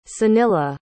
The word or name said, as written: sunila